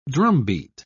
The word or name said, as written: drumbeat